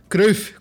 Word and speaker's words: Cruyff: A gente vai falar de Johan Cruyff, como foi conhecido no Brasil, Cruyff, como é a pronúncia em holandês, eu fui procurar, craque da Holanda, craque do Ajax, craque do Barça e técnico que brilhou muito no, no futebol europeu.